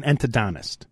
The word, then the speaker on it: endodontist: And let's not forget he's an endodontist.